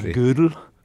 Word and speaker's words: Gödel: I hear it often pronounced, well, I don't speak German, but mathematicians often say it like the woman's piece of clothing, you know, from the old days. It's something like Gödel.